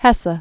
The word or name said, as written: Hermann Hesse